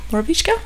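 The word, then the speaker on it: Borovička: Borovichka? – Yes.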